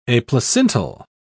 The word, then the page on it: aplacental: British English